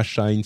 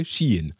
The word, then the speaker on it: Shein: évidemment, bon, Chine, c'est pas Shine, c'est pas euh, toutes les prononciations, c'est Shein en fait.